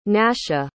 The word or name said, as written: nascha